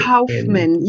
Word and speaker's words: Kaufman: Josh Kaufman. I can spell it for you if you want. – Please do. – Yeah, so Josh and then K-A-U-F-M-A-N. – Oh, Kaufman.